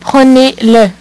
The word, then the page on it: prenez le: French